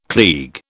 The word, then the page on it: klieg: British English